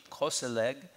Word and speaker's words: koselig: Norwegians also have a word, again, I'll mispronounce this, I'm sure, it's K-O-S-E-L-I-G, koselig, koselig, that means a sense of coziness.